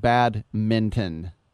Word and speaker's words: badminton: E-Crossminton is a sports video game based in Crossminton, an emerging racket sport, which, yes, looks a lot like badminton, as I pronounce my N. In Minton, but not the G, in orangutan.